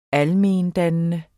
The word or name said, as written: almendannende